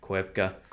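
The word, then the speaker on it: Koepcke: Right. Kwepke. – Kwepke. – I got the pronunciation correct by mistake.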